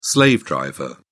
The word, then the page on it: slave driver: British English